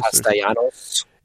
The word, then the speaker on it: Castellanos: Castellanos.